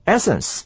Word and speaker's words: essence: 注意到名词的后面那个 essence 呢重音在第一个音节，我们念作 essence，但是这边的形容词呢我们念作 essential。来，